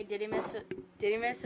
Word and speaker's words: Didymus: By the way, Didymus was right, because I listen to my Bible also when I read it, and that's how they pronounce it.